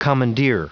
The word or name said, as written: commandeer